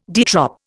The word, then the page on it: de trop: Indian